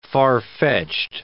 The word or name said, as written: far-fetched